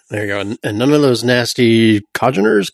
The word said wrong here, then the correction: congeners: Congeners. Congeners. I hear it pronounced.